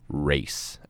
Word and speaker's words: Reyce: It's because, first of all, if you read my name, if it's written out, my name is spelled R-E-Y-C-E, okay? Now, and it's pronounced race, as in a race car, or I am running in a race.